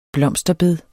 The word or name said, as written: blomsterbed